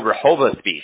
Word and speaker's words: Rehoboth Beach: Oh, Rehoboth Beach.